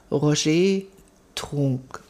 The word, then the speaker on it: Roger Trunk: Er heißt, oder man spricht den Namen von unserem Liedverfasser aus, Roger Trunk. – Also den Vornamen spricht man Französisch aus, den Nachnamen Deutsch.